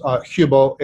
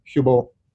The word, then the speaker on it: Hubel: Well, his name is Tomas, spelled like Thomas, Hubel, H-U-B, as in boy, L, Hubel.